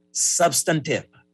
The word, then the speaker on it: substantive: It's sub, S-U-B, plus Stun, S-T-U-H-N, plus T-I-V. So it is a three-syllable substantive, as I pronounce it.